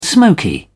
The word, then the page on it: smoky: British English